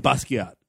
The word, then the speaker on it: Basquiat: Basquiat? Is that, no, I'm saying it wrong. – Basquiat. – There we go.